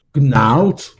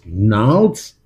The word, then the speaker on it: Gnarls: Das ist Gnarls, Gnarls, Gnarls. Das G spricht man nicht.